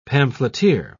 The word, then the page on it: pamphleteer: British English